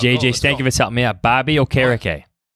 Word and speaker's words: Okereke: So, you got to think about the word carrot cake while you say it. I promise you, if you do that... – You're like a true radio man here. But if you're thinking carrot cake as you say Bobby Okereke, you will always pronounce it correctly.